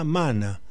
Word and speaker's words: mana: Dice, la pronunciación es fuerza mana. Mana. Pacho, de mana a maná hay un acento de diferencia.